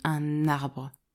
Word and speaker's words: un arbre: Par exemple, quand tu dis un arbre, tu prononces la lettre N du mot 1, comme si elle était collée avec le A du mot arbre.